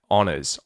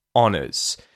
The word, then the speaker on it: honours: The other thing you'll notice because the H isn't said at the start of the word honor or honors, the is pronounced as the, and then we use the y sound to link to the front of the word honors, to that oh vowel sound, the honors.